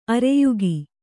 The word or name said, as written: ಆರ್ಯಕೆ